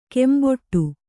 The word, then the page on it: ಕೆಂಬಟ್ಟೆ: kembaṭṭe